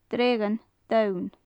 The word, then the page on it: dreathann donn: gaelic